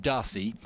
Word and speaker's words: Darcis: And we've been told reliably by the man himself today that actually the, the surname is pronounced Darcy.